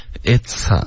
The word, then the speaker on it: it's: Okay, it's 这个 "ts" 的音要爆破。 Okay, 刚刚发成了 is 是 is。而不是 it's OK 这个发音呢会导致误解的 OK